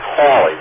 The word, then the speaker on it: Halley: And the fact is that the probable pronunciation was Halley. There are three correct pronunciations in England. Any one is correct. All is known is that Halley was what Queen Anne used, and nobody knows what the, for sure, how the Mr. Haley or Halley or Halley pronounced his name.